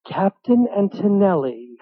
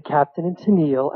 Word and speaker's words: Captain and Tennille: I said, Captain Antonelli. And then on the label was the correct pronunciation. I said, the Captain and Tennille, and I put it on the turntable, and I fell off the chair.